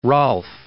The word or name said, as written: Rolfe